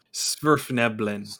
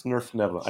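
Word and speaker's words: svirfneblin: I can never say this right. The Snuffleben? – Snurfneblin. – Snurfneblin.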